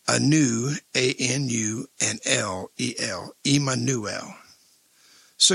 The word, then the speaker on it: Immanuel: In English, Immanuel is pronounced Emmanuel.